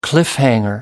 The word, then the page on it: cliffhanger: American English